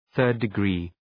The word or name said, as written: third-degree